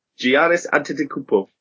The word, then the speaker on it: Giannis Antetokounmpo: Giannis Antetokounmpo. – Damn it, you actually said it correctly.